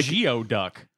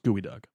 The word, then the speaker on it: geoduck: He's got his dukes up like Geoduck. – Well, that's how it's spelled, but it's pronounced gooey duck.